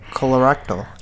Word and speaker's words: colorectal: Like, eating red meat is linked to higher levels of heart disease, stroke, and how do you say this word? – Colorectal cancer. – Colorectal, yeah.